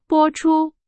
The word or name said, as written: 播出